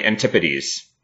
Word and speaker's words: antipodes: And it's apparently antipodes. And we've been saying it wrong.